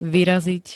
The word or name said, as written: vyraziť